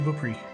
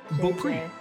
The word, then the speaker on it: Beaupré: I am JJ Beaupré. – How do I spell that? – J, two letters. Beaupré. B-E-A-U-P-R-E. Beaupré.